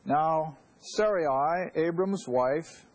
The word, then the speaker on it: Sarai: And Sarai, now I'm using that pronunciation because, see, her name is going to change to A-H in a little while.